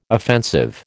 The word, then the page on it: offensive: American English